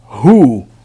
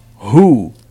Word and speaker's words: who: Como se fosse U-E-N. When. Who. Como se fosse R-U. Who.